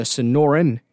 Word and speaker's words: Sonoran: and that's the Sonora Desert. I'm sorry, I pronounced that wrong. The Sonoran Desert, which that's the desert that stretches from southeastern California across southwestern Arizona and into Mexico a little bit.